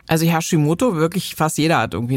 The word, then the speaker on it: Hashimoto: nämlich zum einen den sogenannten Hashimoto.